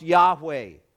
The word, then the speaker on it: Yahweh: It is transliterated into our language as the letters YHWH. It is pronounced Yahweh. In the Hebrew, there are no vowels.